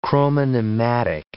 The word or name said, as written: chromonematic